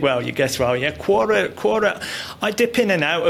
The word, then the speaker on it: Quora: And the last one, exploring Quora. Have I pronounced that? – Quora. Yeah, Quora.